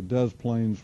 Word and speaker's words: Des Plaines: And if we were to zoom in on this, we would see this is a large park along the Des Plaines River. It's actually how it's pronounced.